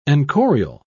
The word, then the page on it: enchorial: British English